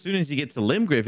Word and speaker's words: Limgrave: that's what it's called, Limgrave.